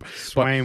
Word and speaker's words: Swaim: The tight end for your uh, Swaim. Swaim, right? – Swaim.